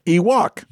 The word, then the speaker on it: Ewok: So instead of Wookiee, Ewok. – There you go. And it rhymes with Miwok, a Native American tribe indigenous to the Redwood Forest in which the indoor scenes were filmed.